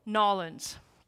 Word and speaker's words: New Orleans: Did I say that right? How do I say it? Nolens.